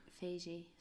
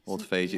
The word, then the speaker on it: autophagy: I think it's called auto autophagy. Do I say that wrong all the time? Autophagy. – Autophagy.